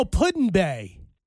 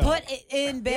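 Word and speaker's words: Put-in-Bay: P-U-T. – Vladimir. That's all I know. – P-U-T space I-N. – Oh, Puddin Bay.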